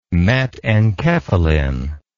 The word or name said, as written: metenkephalin